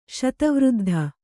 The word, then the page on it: ಶತವೃದ್ಧ: Kannada